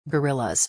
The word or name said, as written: guerrillas